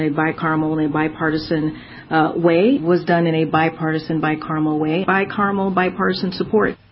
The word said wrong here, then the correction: bicameral: Bi-cameral? – Yes, that would be the appropriate pronunciation for meaning both the House and the Senate.